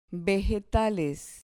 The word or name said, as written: vegetales